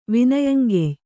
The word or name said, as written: ವಿನಯಂಗೆಯ್